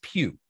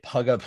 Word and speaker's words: Pugh: Like if you get a random call, like, hey, because people, mine's Pugh, P-U-G-H, but I get Pugga all the time.